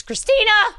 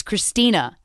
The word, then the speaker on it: Christina: And I know in your head you're spelling it C-H-R-I-S-T-I-N-E. I felt it when you said it. My name's not Christine. It's Christina. But my one grandpa would spell my name K-H-R-S-T-Y-Y-N-A.